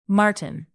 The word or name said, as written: marten